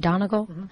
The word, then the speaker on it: Donegal: Donegal? – I think it sounds great.